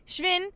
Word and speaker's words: Schwinn: Schwinn rhymes with win, just like Iota, Iota, Iota rhymes with nothing, because there are no competitors.